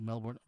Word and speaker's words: Melbourne: Bogut wanted to be the hype switch out his college in favor of his hometown, Melbourne, Australia, and wanted Melbourne pronounced with an Australian accent, not with the Americanized ending that sounds like born.